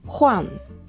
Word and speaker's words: 换: 発音記号は HUAN 第 4 声上から下に急降下する音になります。ホアン、ホアン。